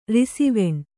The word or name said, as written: ರಿಸಿವೆಣ್